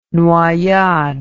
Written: noyade

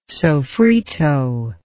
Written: sofrito

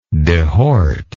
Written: dehorter

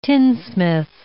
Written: tinsmith

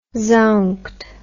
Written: zounked

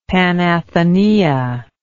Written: Panathenaea

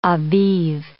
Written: Abib

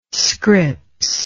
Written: Scripps

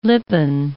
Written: lippen

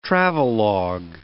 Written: travelog

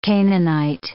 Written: Canaanite

